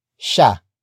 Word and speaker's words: siya: And finally, we have sha. Sha. Sha. Sha is spelled as S-I-Y-A.